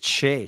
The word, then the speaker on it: Skjei: how do you pronounce that? I said, Che. She goes, how is S-K-J-E-I, Che? I said, I don't know. Just trust me, it's Che.